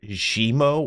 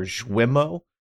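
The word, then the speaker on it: Guillemot: I always say Eve, just like E-V-E, even though it's Y-V-E-S. I think it's Eve. And then I say Guillermo. I've heard it Gimo or Jwimo. But I just say Gimo because it's easier for me.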